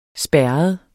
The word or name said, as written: spærret